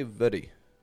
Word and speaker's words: very: We don't go very, very, but ver, almost as if it's like the word air, like what you're breathing right now, air, very, very.